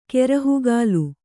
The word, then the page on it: ಕೆರಹುಗಾಲ್: kerahugāl